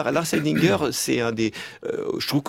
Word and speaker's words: Lars Eidinger: Enfin, au casting du film, donc il y a aussi Lars Eidinger, comment je dois prononcer ? – Lars Eidinger, d'accord.